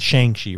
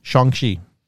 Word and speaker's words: Shang-Chi: So you said Shang-Chi, right? – Yeah. – Which is normal because it's S-H-A-N-G and then Chi, C-H-I. That's actually not how you pronounce that. – How do you pronounce it? – You pronounce it Shang. – Shang-Chi? – Shang-Chi. Yeah, S-H-O-N-G-C-H-E-E. Simu Liu, which is the main character, the lead actor playing Shang-Chi, he tweeted, I think, because this month is Asian Heritage Month.